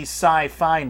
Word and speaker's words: SyFy: It is now the Sci-Fi Network, but it's spelled S-Y-F-Y, so they can trademark Jesus.